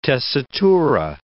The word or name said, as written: tessitura